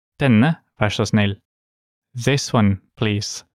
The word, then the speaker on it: vær så snill: In Norwegian, please is vær så snill.